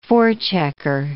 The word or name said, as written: forechecker